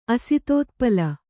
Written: ಅಸಿತೋತ್ಪಲ

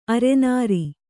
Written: ಅರನಾರಿ

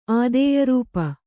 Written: ಆದೇಯರೂಪ

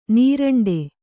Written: ನೀರಂಡೆ

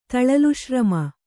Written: ತಳಲುಶ್ರಮ

